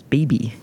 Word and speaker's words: Bibi: I guess our first point of difference is that I refer to her as Bibi. I have the long I. – Okay, yeah, it's probably his Bibi. It's not like Bibi.